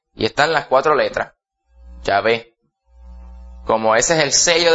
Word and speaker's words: Yahvé: Y en hebreo moderno se pronuncia Yahvé.